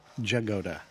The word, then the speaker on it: Jagoda: Say her name again. – Flori Jagoda. – Flori Jagoda. – Jagoda? – Ja. – Jagoda. – Yep.